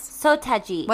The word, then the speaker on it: Seo Taiji: Oh, So Taiji. – What is it? So Taiji. There you go. What you say? Sotaji.